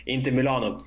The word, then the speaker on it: Inter Milano: Men att rebranda sig lite till Inter Milano har jag, fan vad konstigt jag uttalade Mila, ah, Inter Milano.